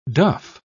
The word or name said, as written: duff